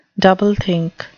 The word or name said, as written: doublethink